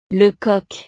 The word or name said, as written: le coq